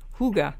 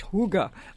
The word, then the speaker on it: hygge: And don't forget, people, it's spelled, The Hygge Life is the name of the book, and it's spelled H-Y-G-G-E, but you pronounce it in the language Huga. You know, H-O-O-G-U-H, Huga, like that.